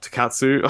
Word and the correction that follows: tokusatsu: I don't want to, like, tonkatsu. Yeah. Oh my god, I almost said tonkatsu is a form of meat. Sorry, it's tokusatsu.